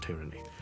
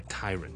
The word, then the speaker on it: tyrant: tyranny。咁啊去到之後我講 tyrant 呢個字咧，我就以為係讀成 tyran 喎。其實是應該是 tyrant 的，所以我們一個極權者我們叫做 tyrant T-Y-R-A-N-T，那個極權我們叫 tyranny T-Y-R-A-N-N-Y。